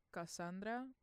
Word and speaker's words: CSNDRA: And then before that, it was Cuida featuring Jandro Blue by Cassandra. And they're a band, but it's spelled C-S-N-D-R-A. So Cassandra without all the A's except for the last one.